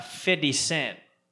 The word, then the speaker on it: 50 Cent: And 50 Cent. Did I say that right? – I think so.